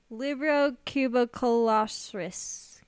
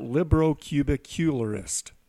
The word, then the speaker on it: librocubicularist: Can I take a guess at how to pronounce? – Yes. – Librocobralorestis. – That's more of a dinosaur. How about librocubicularist? – There you go.